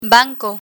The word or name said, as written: banco